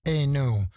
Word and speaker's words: Eno: che si pronuncia Eno, nato a Vonbridge il 15 maggio del 1948.